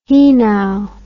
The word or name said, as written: hinau